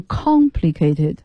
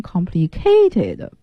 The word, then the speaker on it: complicated: complicated 就是很复杂的啊，就注意大家这个单词的读音，它的重读音节在第一个音节上，是 complicated。因为我教学生的过程中老听见有同学把它发音成 complicated，不对，重音不在 k 上面，是在 con 上面，复杂的形容词。